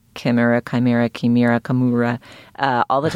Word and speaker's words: chimera: Josephine, how do we pronounce chimera? Chimera. Okay, official.